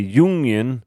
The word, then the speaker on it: Jungian: He immediately establishes that he is not a Freudian like Frasier, that he's a Jungian. I believe that's how it's pronounced there. – Is that Carl Jung?